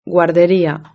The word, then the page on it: la guarderia: Spain Spanish